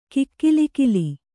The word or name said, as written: ಕಿಕ್ಕಿಲಿಕಿಲಿ